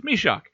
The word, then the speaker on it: Mysak: And I know a lot of people will want to pronounce his name as Jan Mysak. But you go by Elite Prospects and you check out that website. And they have a handy-dandy pronunciation tab that you can click on next to all the names. And it tells you how to pronounce their name as they would. For an example, Jan Mysak. – It's Mysak. When I do the interviews with the players, I always get a promo from them that...